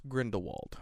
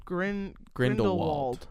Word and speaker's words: Grindelwald: It's the Crimes of Geralt Grindelwald. – Grindelwald. Yeah. That's it. The Crimes of Grindelwald, I think is what it's called.